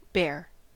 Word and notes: bear: American English

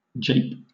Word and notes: jape: British English

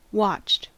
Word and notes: watched: American English

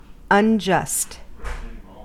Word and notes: unjust: American English

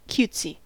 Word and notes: cutesy: American English

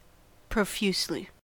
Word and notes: profusely: American English

